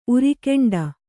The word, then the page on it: ಉರಿಗೆಂಡ: urigeṇḍa